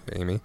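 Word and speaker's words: AmI: The jukebox named Amy, that's capital A-M, small I, pronounced Amy, is Quentin Tarantino's own.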